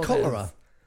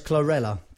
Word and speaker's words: chlorella: Doesn't that say cholera? No, that's chlorella. Chlorella powder, broccoli.